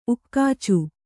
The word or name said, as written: ಉಕ್ಕಾಚು